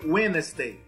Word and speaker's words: Wednesday: Filha, o D é mudo, é Wednesday. – Wednesday. – Isso. – Foi. – Então, o D não, não se pronuncia.